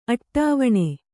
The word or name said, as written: ಅಟ್ಟವಣೆ